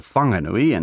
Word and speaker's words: Whanganui: The name's since been corrected to Whanganui, and that's the pronunciation we're using in this podcast, although Pākehā at the time would have spelled it without the H.